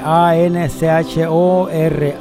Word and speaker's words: Anchor: Anchor se escribe A N C H O R, como en español suena Anchor, pero ese en inglés se dice Anchor